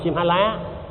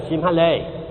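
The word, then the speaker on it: Sinhala: Người Tích Lan không đọc là Simhala, mà người Miến thì để quên, người Tích Lan đọc chữ này là Simhale.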